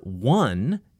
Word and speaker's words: one: The number one is spelled with an O, but it has a W sound, which is a consonant sound, just in case you're confused.